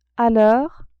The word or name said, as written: a l'heure